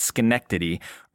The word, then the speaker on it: Schenectady: Alex is a 52-year-old personal finance manager from Schenectady, New York. Oh, yeah, I made sure to pronounce that right, Schenectady.